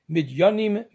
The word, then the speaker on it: Midjanim: It is spelled different, it is pronounced differently. Midjanim, Midanim.